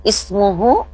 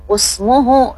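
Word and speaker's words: ismuh (اسمه): maka kita membacanya hati-hati bukan usmuhu ya. Kalau menurut teori di atas kan ini huruf ketiganya berharokat dhamma dibacanya U, tapi kalau ini pengecualian dibacanya ismuh ya, ismuh.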